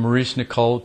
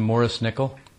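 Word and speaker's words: Maurice Nicoll: Remember when we changed from Maurice Nicole to Morris Nickel? We started to pronounce it correctly because we learned.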